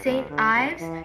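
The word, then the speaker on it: St. Ives: Thì hãng này đọc đúng là Saint Ives, nhưng mình thấy là nhiều bạn cũng đọc sai, nhưng cũng không sao, phải không?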